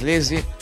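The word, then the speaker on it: Lazy: si bien se escribe si o sea, L-A-Z-I, la pronunciación es Lizzy, y lo van a escuchar de una manera increíble en esta mezcla de Manuel Bustos para el especial de Express 2.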